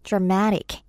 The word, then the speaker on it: dramatic: 발음하실 때 dramatic 이음절의 강세 넣으시면 돼요. dramatic 또는 dramatic.